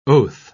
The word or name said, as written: oath